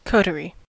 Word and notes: coterie: American English

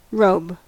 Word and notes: robe: American English

